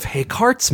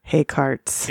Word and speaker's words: Hayscartes: It's one of Haycart's methods. – You know Haycart's? – Oh my god, that pun is so good. – Haycart's. – It is spelled like Descartes, but with hay. – Yes. – H-A-Y-S-C-A-R-T-E-S.